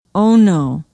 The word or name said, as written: ono